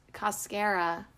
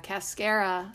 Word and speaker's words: cascara: Barberry, blue flag, cascara, because it rhymes with mascara, cascara, sagrada, dandelion root.